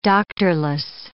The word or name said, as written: doctorless